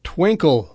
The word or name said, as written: twinkle